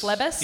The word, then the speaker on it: Phlebas: For Firefly, for example, they say you may want to check out, consider, I can never say the Phlebas, Ian M. Banks. – I think it Phlebas? – Phlebas. – Ian Banks. – Phlebas. Phlebas. Yeah. Phlebas. – Yeah. Phlebas. Consider Phlebas. Consider learning how to pronounce this and then ordering it at your bookstore.